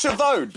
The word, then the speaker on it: Schiavone: It's pronounced Chavone because Chavone is how I've always said Schiavone's surname as a joke. – Right. No, but that's how you actually pronounce it.